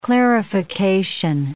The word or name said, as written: clarification